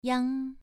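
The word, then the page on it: yang: tone 2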